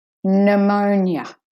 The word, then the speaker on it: pneumonia: Okay, the first word is pneumonia, so the P is silent.